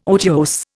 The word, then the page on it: otiose: Indian